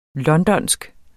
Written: londonsk

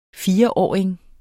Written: fireåring

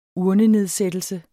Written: urnenedsættelse

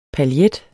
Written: PAILLET

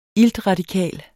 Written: iltradikal

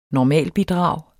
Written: normalbidrag

